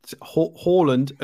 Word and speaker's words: Haaland: That's Haaland and Udegaard, that's how you're supposed to say it.